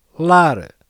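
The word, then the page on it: lar: European Portuguese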